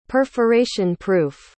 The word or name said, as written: perforation proof